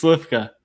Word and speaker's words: Slywka: It's Chris Slifka.